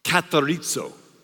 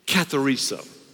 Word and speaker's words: katharizo: The word framed, you're probably wondering, in the Greek is pronounced katharizo. Say it with me, katharizo. It almost sounds Italian.